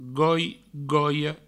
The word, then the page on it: goi, goia: goi; goio